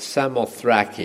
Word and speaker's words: Samothrace: I think Monica got that just right. Samothrace is exactly how it should be pronounced, something like that. I'm just reading it phonetically.